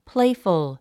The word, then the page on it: playful: pléifəl; プレイフル